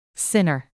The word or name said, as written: sinner